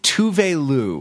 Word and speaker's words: Tove Lo: We are talking about the Swedish singer Tove Lo, which we just found out that that's how to pronounce her name.